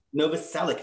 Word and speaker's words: Novoselic: Plus, we get Krist Novoselic. – Novoselic, thank you.